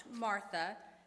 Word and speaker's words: Martha: We know them as Martha and Mary, but in their own tongues, they were probably pronounced Martha and Mara.